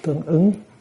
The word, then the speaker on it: tương ứng: Tương ưng đáng lý là mình phải đọc là tương ứng, là nó đi đôi với nhau, nó cộng tác với nhau, nó ăn rơ với nhau, là tương ứng.